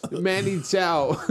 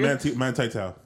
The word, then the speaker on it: Manti Te'o: Yo, Manti Te'o. Wait, I said it wrong. – Manti Te'o.